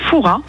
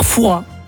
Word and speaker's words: Fouras: Eh bien écoutez, on dit Foura. Ah ben, on ne prononce pas le S. – Et donc on dit bien Père Foura. – Voilà, tout à fait.